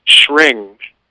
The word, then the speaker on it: Shring: The second variation is the same, you're chanting to the same goddess, Lakshmi, but it's a tantric pronunciation, and this one is Shring. It's like ring with an S-H on it, mm-hmm. S-H-R-I-N-G. Shring, Shring, Shring, Shring, Shring, Shring. Or you could blend the two. Shreem, Shring, Shreem, Shring.